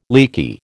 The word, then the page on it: leaky: American English